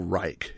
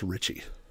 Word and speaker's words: Reiche: I am sure I have butchered his name as Paul Reich. It is R-E-I-C-H-E, but it is pronounced Ritchie.